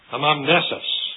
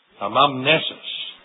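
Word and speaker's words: anamnesis: Amamnesis is the Greek word. Amamnesis. If I pronounced it in an anglicized form for you, amamnesis.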